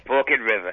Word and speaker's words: Forked River: F-O-R-K-E-D and then river, and they pronounce it Forked. – Forked? – Yeah. – Do they? – Forked River. It's not my accent. It's that's how they say it.